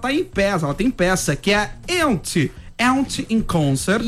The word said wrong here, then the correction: Yentl: Yentl. – Eu vou fazer com pronúncia Yentl in Concert.